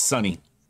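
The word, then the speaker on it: Suni: It's pronounced Canada Sunny. Tip. It's Sunny. Like Sunny Johnson.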